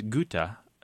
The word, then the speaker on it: gutta: But these two words come from the same Latin word as the acronym that you gave us, the G-T-T-S. And the singular is gota, G-U-T-T-A.